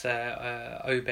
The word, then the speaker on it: Obe: It's Amir, and it's Obe as in O-B-E, right?